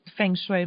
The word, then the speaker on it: feng shui: I didn't feng shui my bedroom.